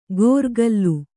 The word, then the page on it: ಗೋರ್ಕಲ್ಲು: gōrkallu